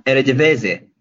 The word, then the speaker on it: Eredivisie: Or do you watch actually Arrivederci or what, how do you pronounce the uh, the um, the Dutch league actually? um, Eredivisie. – Eredivisie. Ah, that's it.